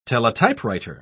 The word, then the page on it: teletypewriter: British English